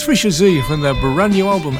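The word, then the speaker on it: Fischer-Z: Some people pronounce it Fisher Z. It's Fisher Z.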